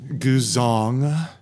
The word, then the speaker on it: guzheng: I believe it's pronounced Guzong.